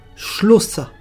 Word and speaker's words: slota: Ça s'écrit slota, S-L-O-T-A, et ça se prononce shlosa. Les évangiles ont écrit posefie. Ça s'écrit E-U-S-E-V-E-I-S, E-Z-V-I-S, et ça se prononce posefie.